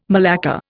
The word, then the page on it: malacca: Indian